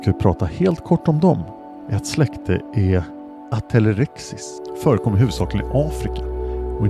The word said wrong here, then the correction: Atelerix: Bland Atelerix, så uttalas det, förekommer afrikansk pygmeigelkott.